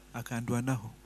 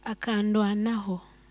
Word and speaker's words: Akandwanaho: His real names are Caleb Akandwanaho. Eh? How do you pronounce it. – Akandwanaho. – Let me whatever. This one is what? – Akandwanaho.